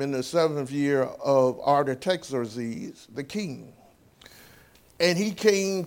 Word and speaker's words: Artaxerxes: Artaxerxes is how I should have pronounced that, Artaxerxes.